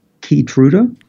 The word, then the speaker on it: Keytruda: Keytruda.